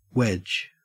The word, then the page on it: wedge: Australian English